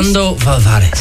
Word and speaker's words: ¿Cuándo volverás?: And she said, ¿Cuándo volverás? – Right? ¿Cuándo volverás? – Sorry, beautiful, beautiful pronunciation.